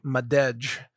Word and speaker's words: Madej: If you want to search for her name, her last name, Madej, is actually spelled M-A-D-E-J. Like Madej, but she pronounces it Madej.